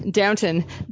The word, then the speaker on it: Downton: How do you say it? – Downton. Downton Abbey.